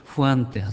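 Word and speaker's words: Juan: 例えばジョンという呼び名、聖書に出てくるヨハネのことジョンと言うでしょ、ね、でも北欧ではヨハン、ドイツなんかでもヨハンかな、そしてイアンって発音するのはフランスですか、ちょっと私は分からないけども、このスペイン語系ではフアンって発音しますしね、まあ、いろんな発音の仕方があるわけですけども、とにかくごくありふれた名前でした。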